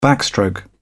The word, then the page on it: backstroke: British English